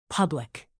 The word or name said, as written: public